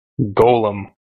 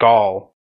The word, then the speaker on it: Golem: Golem. Not Gaul. – I just call him Smeagol. So that makes it easier to tell difference from Gollum. – I've always pronounced, you know, Smeagol as Gollum and Golem as Golem, because it's an O. – A golem to me is... – Like you say hello, not hala.